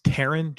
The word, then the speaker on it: Taron: I have to arrest you for a pronunciation error that you made. – Okay. – It's Taron Johnson.